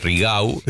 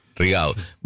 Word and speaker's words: Rigau: de la detención de Chocolate Rigau. – Sí, sí. Se pronuncia así, porque es catalán, no es francés. Digo, porque alguno cuando lo aprende dice, Rigau.